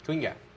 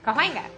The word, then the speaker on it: Kawinga: It's like a couple of pronunciations. Quinga. – Schwinger. – Quinga. Quinga. – Okay. Quinga. The queen guy. – Kawinga. – Kawinga? Like Kawinga. – Not quite. – Kawinga.